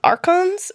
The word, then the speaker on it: archons: The other thing is, like, a law bringer, which comes back to archons. Is that how you say it? – Yep. – Archons.